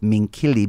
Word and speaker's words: Minchilli: So I can't say Elizabeth Minchilli because that's the Italian pronunciation, and the English spelling is Minchilli.